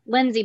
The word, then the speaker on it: Lyndsey: And my personal Instagram is Lindsay, but my name is spelled funny.